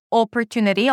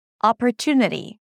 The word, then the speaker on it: opportunity: Ah, ah, it's not opportunity, although it's spelled with an O. No, think about it like it has two A's. Ah, ah, opportunity.